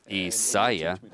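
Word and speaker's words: Исаия: И это имя правильно произносится Исайя, научите меня правильно произносить.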